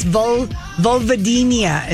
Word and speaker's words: vulvodynia: By the way, Rocky Horror said it's vulvodynia is how you pronounce that.